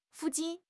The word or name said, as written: fu4 jin4